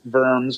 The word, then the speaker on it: Worms: It's pronounced Worms, but it's W-O-R-M-S, Worms, which is the oldest town in Germany.